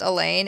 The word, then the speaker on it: Alain: It's also that unusual spelling of Alan with an A. Sometimes pronounced Elaine, A-L-A-I-N.